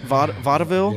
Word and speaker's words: vaudeville: Is that how you say it? Vaudeville? – Yes, it is exactly how you say it.